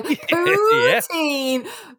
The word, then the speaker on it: poutine: Poutine. And if you just literally, if you Google P-O-U-T-I-N-E, one word. – Yeah, you were to say poutine on you. Like, I could tell you, like, poutine.